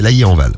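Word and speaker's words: Lailly-en-Val: On file dans le 45, si vous nous écoutez dans le 45, on va à Laily en Val, je sais pas si je prononce bien, ou Laily en Val, c'est Laily en Val.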